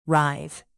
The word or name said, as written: writhe